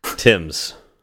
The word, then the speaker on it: Thames: We couldn't figure out how to say it. Tim's.